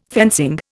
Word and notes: fencing: Indian

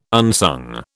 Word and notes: unsung: British English

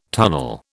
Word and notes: tunnel: British English

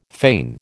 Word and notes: fane: American English

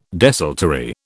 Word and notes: desultory: British English

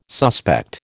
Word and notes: suspect: American English

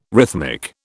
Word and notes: rhythmic: British English

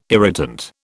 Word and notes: irritant: British English

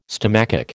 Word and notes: stomachic: American English

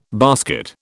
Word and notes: basket: British English

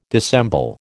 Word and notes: dissemble: American English

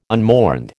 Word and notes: unmourned: American English

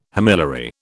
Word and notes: homiliary: British English

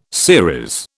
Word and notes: series: British English

